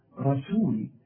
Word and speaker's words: رسول: هنا بقى في مشكلة في نطق رسول، ناس كتير قوي تحول لي السين الرؤياء إلى صاد، حتى لما يجوا يسبحوا يقول لك سبحان الله سبحان الله، هي سين ولا صاد؟ طب ما هذه سين رسول،